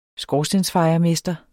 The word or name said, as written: skorstensfejermester